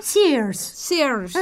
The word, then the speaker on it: Sears: Sears. Eso, ¿qué? Pronuncias muy bien.